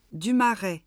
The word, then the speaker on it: Dumaret: Dumaret. D-U-M-A-R-E-T.